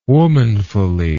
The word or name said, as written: womanfully